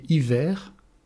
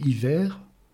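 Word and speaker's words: hiver (עיור): La peau, la tunique de peau qui recouvre l'homme, c'est un aïn, un vav et un resh. Prononcé « aor », c'est la tunique de peau, la peau. Prononcé « hiver », ce sont les mêmes lettres. Prononcé « hiver », cela signifie la cécité, être aveugle.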